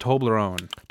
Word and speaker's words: Toblerone: And I believe it's pronounced Toblerone. – Toblerone? – Yes.